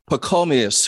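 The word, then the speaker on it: Pachomius: But I want to go to the other monk, how do you pronounce it? – Pacomius, who didn't allow a bunch of demons to distract him. Please tell that story. – Pacomius was a really influential founder of monasteries in Egypt.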